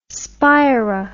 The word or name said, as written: spira